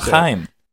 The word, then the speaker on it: Haim: Haim.